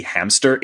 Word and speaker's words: Hamcester: Likewise, Hamcester is pronounced hamster, like the rodent, which I thought was like a very cute, funny thing.